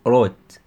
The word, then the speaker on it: wrote: he wrote الW دي لا تنطق خلاص كده لا تنطق بتقول wrote wrote